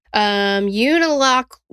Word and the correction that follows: Unalakleet: no, Unalakleet. Okay, got it. Like mm-hmm. I was struggling with it because it's the way it's spelled and the way it's pronounced are a little different.